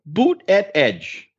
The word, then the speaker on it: Buttigieg: Buttigieg himself suggests that people should pronounce it like Buttigieg.